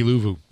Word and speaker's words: Luvu: And when Carr got sacked by who's an outstanding player, this guy got over 100 tackles, like Luvu, whatever, however you pronounce his name. – Yeah, Frankie Luvu.